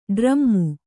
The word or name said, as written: ಡ್ರಾಮು